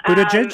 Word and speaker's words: Buttigieg: Buttigieg, am I pronouncing that right? – Pete Buttigieg, um, yeah.